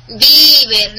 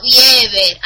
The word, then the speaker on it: Bieber: Y por cierto, se pronuncia Bieber, no Bieber, analfabeto de mierda.